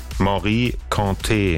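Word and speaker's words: Mory Kanté: Wie wird's ausgesprochen? – Morikante. – Achso, guck mal. Das war also falsch.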